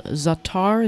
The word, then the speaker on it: za'atar: There's a spice called za'atar.